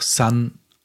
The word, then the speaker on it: son: Und das Wort Sohn heißt auch, wird auch Sun ausgesprochen mit S-O-N. Sonne mit S-U-N und der Sohn mit S-O-N, aber beides hört sich wie Sun an.